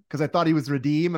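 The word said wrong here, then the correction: Radim: and I sent the tweet that was Redeem the Dream, and I'm like, you idiot. I mispronounced his name.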